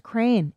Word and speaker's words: crayon: Because they're like, no, it's crane. It's crane. We're from L.A. Everybody says crane.